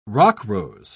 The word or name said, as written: rockrose